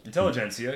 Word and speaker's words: intelligentsia: The keywords, intelligentsia. How do you say that? – Intelligentsia. – Intelligentsia.